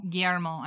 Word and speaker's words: Guillermo: So Antlers is about the legendary Wendigo, and it's the new movie from, help me with his name because I always say it wrong, Guillermo. – I'm so sorry. – Guillermo. – I'm really terrible with the Latin languages.